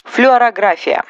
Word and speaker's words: флюорография: Флюорография. Врач вас отправляет на флюорографию, но вы его не слушаете. Точнее, на обследование идите обязательно, а вот перенимать неправильное ударение, принятое в медицинской среде, не нужно рефлексия.